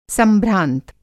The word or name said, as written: संभ्रांत